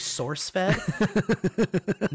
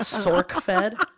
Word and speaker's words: SourceFed: I hate when people say it wrong because it's actually SourceFed, not SorkFed.